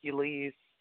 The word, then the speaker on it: Ulice: It doesn't mean anything specifically, and it does not sound like it's spelled. As far as I know, it was some basketball player back in the 70s, I guess, that they knew of or heard of and liked the name and decided to keep the name but spell it a different way because everyone calls me Ulice instead of Ulice.